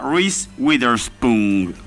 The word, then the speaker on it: Reese Witherspoon: Es la protagonista Reese Witherspoon, es el nombre de la chica.